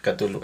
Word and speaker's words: Cthulhu: Pero bueno, respecto a este libro, el, La llamada de Cthulhu, yo lo pronuncio así, Cthulhu. Eso es algo que me han enseñado. Pero bueno, como ya lo mencionó Marilu, no hay corrección,